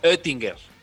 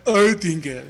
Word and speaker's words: Oettinger: Tienes que decir la O y poner la boca como si dijeras E. Es algo así como Oettinger. Pero bueno. – Oettinger.